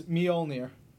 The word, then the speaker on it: Mjolnir: No, it's Mjolnir. So how do you pronounce it? – Mjolnir. – Y'all. – So it's more like a mule.